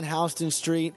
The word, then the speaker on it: Houston Street: Yeah, and you know, I'm walking down Houston Street, and you know, because I know how to pronounce it correctly. And so I'm walking down Houston Street, and, you know, I saw this thing out of the corner of my eye.